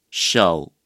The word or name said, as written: 手